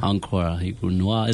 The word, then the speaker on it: Encore noir: So from hosting the Pan-African Poets Cafe to headlining, creating and curating platforms for sold-out events like, can you pronounce this one for me? – Encore noir. – Is that, that's a French term, yeah.